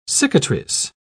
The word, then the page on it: cicatrice: British English